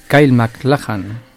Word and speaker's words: Kyle MacLachlan: Kyle MacLachlan,